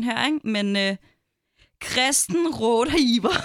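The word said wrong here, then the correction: Rodeheaver: Kristen Rodehiver.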